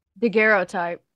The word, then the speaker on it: Daguerreotype: Daguerreotype.